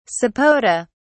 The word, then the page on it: sapota: British English